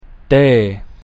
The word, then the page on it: D: Latin